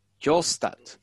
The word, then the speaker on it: Kjølstad: Kjolstad.